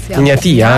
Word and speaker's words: Egnatia: Già domenica, quindi tra pochi giorni, ci sarà la prima partita contro l'Egnatia, così si pronuncia. – Egnatia, insomma, il campione di Albania.